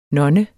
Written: nonne

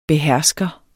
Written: behersker

